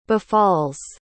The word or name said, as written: befalls